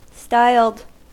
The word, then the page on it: styled: American English